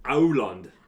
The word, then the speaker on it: Aurland: Try to say it. – Aurland. – That's pretty good. It is Auland.